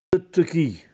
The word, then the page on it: Addettechì: Castellanetano